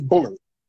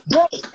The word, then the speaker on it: Bullard: Like, you know, I hate when somebody say Bullard. Motherfucker. Bullard. My name is Bullard. And I know people say it, I get all that, but my name is spelled Bullard. That's my name. Where is Lurd? Where is that? My name is not Bullerd.